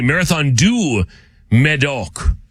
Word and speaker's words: Marathon du Médoc: Marathon de Médoc. I'm sure I said that wrong. Or excuse me, Marathon du Médoc.